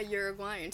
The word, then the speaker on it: Uruguayan: Yeah, Uruguayan. So like the place is Uruguay, but like you know how like a someone from Puerto Rico would be like Puerto Rican? – Mm-hmm. – So Uruguay, you're Uruguayan.